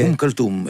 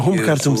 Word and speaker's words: Umm Kulthum: Esse músico que eu não vou ousar pronunciar, que tu disseste anteriormente, é Umm Kulthum, é uma cantora egípcia. – Ah, Umm Kulthum, sim.